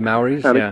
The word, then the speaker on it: Maori: So he laughed and said, no, he goes, Godstix are, this is really interesting to me, it's folklore of the Maori, I think I'm pronouncing that right, tribe of New Zealand. – The Maoris, Maori, that's it,